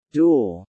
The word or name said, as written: dual